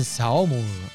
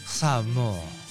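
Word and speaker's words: Saumur: Nació en Saomur Yo creo que se dice así. – En verdad se diría Saomur porque todo lo francés suena como mucho amor.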